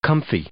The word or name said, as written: comfy